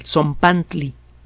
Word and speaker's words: Tzompantli: Zompantli. Se escribe T-Z-O-M-P-A-N-T-L-I. Zompantli.